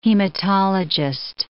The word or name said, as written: hematologist